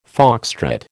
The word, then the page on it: foxtrot: American English